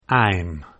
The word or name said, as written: I'm